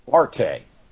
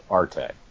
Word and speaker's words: Arte: It's pronounced Arte. You know, I did a little work on this. I've been known to listen to other podcasts, and without giving it away who it was, I was listening to one anime podcast, and he argued with this girl that it's art. It's pronounced art. You wouldn't say haute couture.